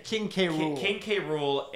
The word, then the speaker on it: King K. Rool: But I just thought it was interesting, first of all, because they use, how do you say it, King Krule or whatever? – King K. Rool and Ridley are used...